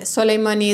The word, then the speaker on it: Soleymani: Nafise is NAFISEH, and Soleimani is SOLEYMANI.